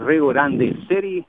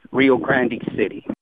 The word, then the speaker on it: Rio Grande: Now, if you pronounce it in Spanish, it's Rio Grande City, but of course the English version is Rio Grande City.